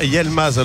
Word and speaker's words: Yilmaz: Est-ce qu'on ne doit pas dire Yilmaz alors, puisque le I se prononce E. Donc exact. – On a Y-I, donc c'est Yilmaz. – Yilmaz. – Yilmaz, pas Yel.